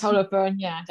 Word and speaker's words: Holofernes: Yeah, it's Holofernes.